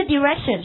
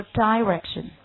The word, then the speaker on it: direction: 好， by the way，顺便说一下，这个 direction 是我们英式发音，如果是美式发音它是不读 direction，它会读 direction。好，那么这个字母在这里面英式当中发 A，美式当中发 I， direction。